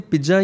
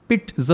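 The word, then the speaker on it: pizza: तो कहते हैं पिज्जा वाह जी वाह पिज्जा पंजाबी में ही स्पीक्स तो मैं कहता हूँ इसे पिज्जा या पिज्ज़ा नहीं इसकी सही प्रोनाउंसिएशन पिट्ज होती है इसमें ट का साउंड आता है इसमें टी हाँ।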